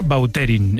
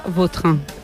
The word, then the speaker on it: Vautrin: Es que a mí me suena mejor Bauterín. Es como... – Da más fuerza, ¿no? – Más fuerza, más pronunciación. – Elegante. – La original francesa, Vautrin.